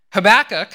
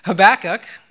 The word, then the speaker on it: Habakkuk: Habakkuk, that's how you say it. Sarah did a great job. I Googled it also myself to find out. It's not readily obvious, I assure you. Habakkuk, he was a prophet.